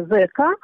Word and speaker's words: ЗК: И уже в официальной лагерной документации появляется вот это вот сокращение ЗК. Именно так это произносилось.